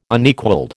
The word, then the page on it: Unequalled: American English